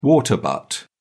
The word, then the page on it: water butt: British English